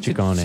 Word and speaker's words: Ciccone: Veronica Ciccione. – Ciccone. – Ciccione. What is it, sorry? – Well, in Italian, it works the other way. So the double C's are hard C's and the singular C's are soft C's.